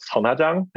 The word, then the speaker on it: Sun Ha Jang: It's called Sonhajang. Okay. And it's spelled S-U-N space H-A space J-A-N-G.